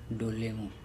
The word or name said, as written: dolemu